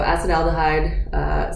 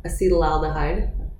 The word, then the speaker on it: acetaldehyde: So acetaldehyde, some other people pronounce it acetylaldehyde. I have a hard time saying it that way since I'm used to pronouncing it the other way, so that's what I'm going to do.